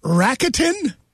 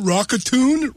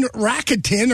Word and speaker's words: Rakuten: I saw it advertised on TV and I didn't know how to pronounce it. And then their advertisements were actually making fun of the pronunciation. So people are like, funny. – People are like, well, Rockatoon, whatever.